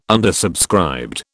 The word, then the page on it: undersubscribed: British English